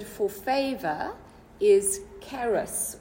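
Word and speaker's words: Charis (χάρις): There we go, with the Greek accent. Okay. It's charis. – Charis, charismatic. – See. Okay. So the Greek word for favor is charis,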